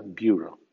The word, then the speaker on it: bureau: En las siguientes condiciones, EAU intermedio o final suena OU o IU. Así, bureau suena bureau.